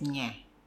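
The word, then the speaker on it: ñ: But the pronunciation of NG is nga, and the pronunciation of enye is nye.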